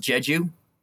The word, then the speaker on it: Jeju: You know, like I know like you can spend like a week just at um uh I think it's supposed to be pronounced Jeju even oh, though we Jeju all say Island?